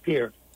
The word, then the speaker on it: Pierre: Well, most people pronounce it Pierre, which is the state capital, but in-state it's called Pierre.